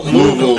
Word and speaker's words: Louisville: Except for you said Louisville. – I know, I did say it wrong. – Is it Louisville? – It's Louisville. – Louisville. – Louisville. Like, kind of like Missouri.